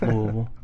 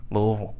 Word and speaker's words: Louisville: Don't say it like you're not from Kentucky. It's Louisville. – Louisville.